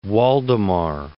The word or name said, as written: Waldemar